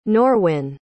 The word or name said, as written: norwin